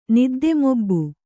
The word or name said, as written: ನಿದ್ದೆಮೊಬ್ಬು